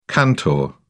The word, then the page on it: cantor: British English